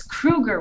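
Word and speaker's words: Kroeger: And it is Kruger, right? Because, you know, 50% of the internet says Kroger. 50% of the internet says Kruger.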